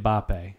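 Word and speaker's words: Mbappé: And this, I'm going to butcher this name, Kylian Mbappe, French soccer forward. He was born this year as Mbappe. – Well. Mbappe? – Kylian Mbappe, yeah.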